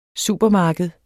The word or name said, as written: supermarked